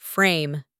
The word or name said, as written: frame